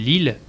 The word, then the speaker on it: Leelh: Et en parallèle de ça, on a un projet qui s'appelle Lille, donc qui se prononce phonétiquement comme la ville, mais qui s'écrit L-E-E-L-H, et